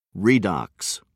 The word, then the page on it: redox: American English